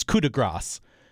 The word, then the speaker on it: coup de grace: It's coup de grace.